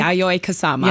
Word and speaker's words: Yayoi Kusama: Yayoi Kusama.